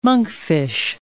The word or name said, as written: monkfish